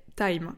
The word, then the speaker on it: Tiime: Ensuite, le troisième outil, c'est Time. Alors, Time, en fait, ça s'écrit d'ailleurs T-I-I-M-E.